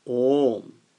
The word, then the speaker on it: om: A and u are joined together. A plus u according to Sanskrit grammar is o. So, you cannot pronounce om. So, you have to pronounce it as om.